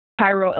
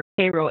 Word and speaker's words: Cairo: And they were going to be transported from Camp, Camp Fisk at Four Mile Bridge to Cairo, Illinois, or Cairo, Illinois. I've heard it pronounced either way.